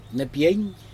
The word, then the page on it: nepjeg: Aneityum